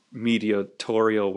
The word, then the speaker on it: mediatorial: Now, we're going to actually say pretty soon, probably next podcast, that Amos's mediatorial work, is that how you say it? – Yeah, you got it.